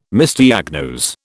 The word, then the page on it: Misdiagnose: British English